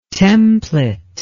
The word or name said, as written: templet